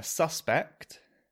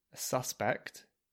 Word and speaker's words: suspect: More examples, we've got a suspect, suspect, which is a noun, and to suspect, which is the verb. So suspect and suspect. So see, in a noun, the first syllable, sus, is stressed. And in the verb, it's the last syllable, suspect.